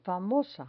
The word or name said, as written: famosa